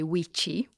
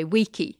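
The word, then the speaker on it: vici: So that we don't say Caesar, we say Kaiser, and we don't say Weechi, we say Weeki.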